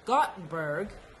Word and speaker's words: Gothenburg: And you've also spent some time in Gothenburg, Sweden.